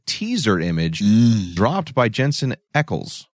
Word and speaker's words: Ackles: Repeat after me, Thomas. Ackles. – Ackles.